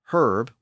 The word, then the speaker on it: herb: Sometimes both pronunciations survived, like H-E-R-B. In Britain, herb with the H one out, but in America, herb without the H one out.